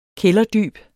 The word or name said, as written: kælderdyb